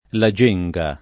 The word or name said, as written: Genga, la